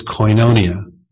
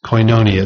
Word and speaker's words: koinonia: The Greek word most often translated fellowship in the New Testament is koinonia. Koinonia. It's spelled K-O-I-N-O-N-I-A.